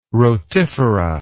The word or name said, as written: rotifera